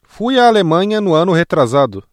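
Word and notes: ano retrasado: Brazilian Portuguese

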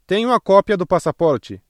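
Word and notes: cópia: Brazilian Portuguese

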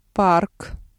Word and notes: парк: Russian

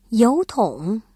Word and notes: 邮筒: yóutǒng

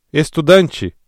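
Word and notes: estudante: Brazilian Portuguese

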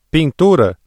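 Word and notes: pintura: Brazilian Portuguese

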